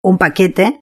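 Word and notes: un paquete: Spanish (Spain)

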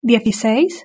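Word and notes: dieciseis: European Spanish

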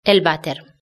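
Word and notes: el vater: Spanish (Spain)

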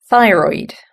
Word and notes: thyroid: British English

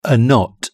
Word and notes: a knot: British English

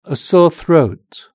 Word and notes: a sore throat: British English

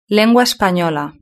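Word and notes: lengua espanola: Spanish (Spain)

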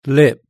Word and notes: lip: British English

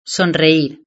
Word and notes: sonreir: Spain Spanish

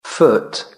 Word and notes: foot: British English

